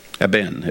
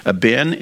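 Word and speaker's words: Eben (אבן): The Hebrew word for stone is Eben. Eben, if I pronounce it out by the syllables. Eben is a compound Hebrew word.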